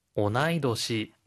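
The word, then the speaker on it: 오나이 도시 (同い年): 오나이 도시. – 오나이 도시. 오나지 뒤에 연결될 때는 토시 이 토가 청음인데요. 오나이 도시라고 할 때는 토 땡땡 붙여서 도라고 발음해야 합니다.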